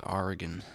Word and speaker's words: Oregon: Portland, Oregon. – Yeah. – I think is the correct pronunciation. – Oregon? – Oregon. – Oregon? – Or Oregon. I just know it's not gone.